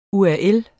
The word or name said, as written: URL